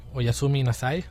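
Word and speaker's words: Oyasumi Nasai (おやすみなさい): També et puc dir-te Oyasumi Nasai. – Oi, assumint assai. – Ho dit bé? La pronunciació és correcta? – Ho has dit molt bé.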